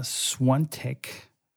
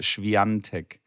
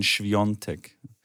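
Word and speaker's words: Świątek: Dass Nein, man, man, man spricht sich, also so, so, wie SCH und dann W-I-A-N-T-E-K, Schwiontek. Und das so ein bisschen Schwiontek. Ja, Schwion mit O wahrscheinlich, Schwiontek, ja.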